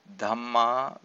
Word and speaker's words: Dhamma: Dhamma with a long A is another important word, which means the same as kamma bija.